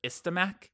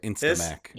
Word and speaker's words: Istamak: It just says, there's only one word that's still legible, and it says Istamak. – It's the Mac? – It's the Mac. – Istamak. – Spell it for me. – Whenever we need Mac here, we get Instamac. – Instamac. – I-S-T-A-M-A-K.